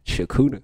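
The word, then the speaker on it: Chakuta: Shakuta. – Shakuta? – Yeah, like right. It sounds like S-H, but you spell it C-H.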